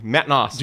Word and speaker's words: Naas: No, it's like two syllables. It's like pop, pop. And like Matt Naas.